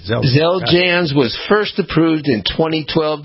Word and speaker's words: Xeljanz: It's X-E-L-J-A-N-Z.